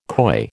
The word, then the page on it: COI: American English